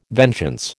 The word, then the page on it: vengeance: American English